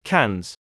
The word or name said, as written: cans